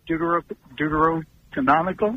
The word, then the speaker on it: Deuterocanonical: And my question is when, because I know they the Deuterocanonical.